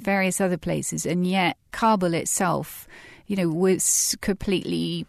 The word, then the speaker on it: Kabul: Christina, you have a chapter about Kabul.